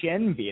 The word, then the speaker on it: Shenvi: It's Shenvi. It's very phonetic, actually.